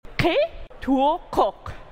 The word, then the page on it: xe twøǩox: Phyrexian